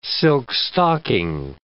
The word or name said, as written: silk-stocking